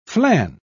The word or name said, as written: flan